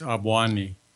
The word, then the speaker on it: Obwani: My name is Avwani. – Avwani? – Yes. – Could you English spell that for me? – O-B-W-A-N-I.